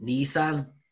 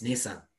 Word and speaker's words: Nissan: He called it Nissan, and it's like, it's Nissan.